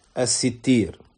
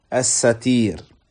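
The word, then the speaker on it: الستير: Parmi les noms d'Allah subhanahu wa ta'ala, il y a As-Sittir. Certains savants disent que ça se prononce plutôt As-Satir.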